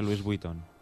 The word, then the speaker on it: Louis Vuitton: Se pronuncia Louis Vuitton.